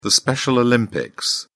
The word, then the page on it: special olympics: British English